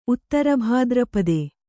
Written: ಉತ್ತರಭಾದ್ರಪದೆ

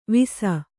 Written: ವಿಸ

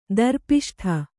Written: ದರ್ಪಿಷ್ಠ

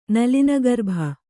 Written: ನಲಿನಗರ್ಭ

